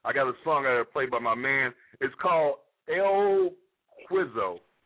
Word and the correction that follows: El Queso: Pronounce it right for me. – El Queso. – Sir? – El Queso, El Queso. E-L-Q. – El Queso? Okay.